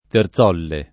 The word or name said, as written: Terzolle